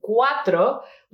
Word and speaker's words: cuatro: And here, as you can see it, we also have it after in cuatro. We have to pronounce the C-U-A, not as an S, as in these cases, but like a K sound.